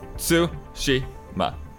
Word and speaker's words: Tsushima: I think the weird thing is Japanese theoretically doesn't have accents. – Right. – So that all the syllables are even. But so it's Tsushima.